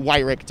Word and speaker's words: Wyrick: Wyrick, W-Y. It's German. – Interesting. – Oh, W-Y. – Wait, spell it for us. – W-Y-R-I-C-K.